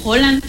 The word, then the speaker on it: Haaland: Pues ahora ya les están poniendo nombres noruegos, que por decir, también me da mucha risa porque Haaland no se dice Haaland. Se dice como Holland.